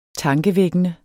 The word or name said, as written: tankevækkende